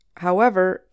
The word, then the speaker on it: however: In spoken English, however can be used as a more forceful way of saying how. When we use it this way, we stress the second part of the word, ever.